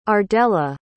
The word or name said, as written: ardella